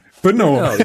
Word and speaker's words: Brno: Brno? How's it pronounced? – Brno. It's B-R-N-O. It's actually just a four-letter city.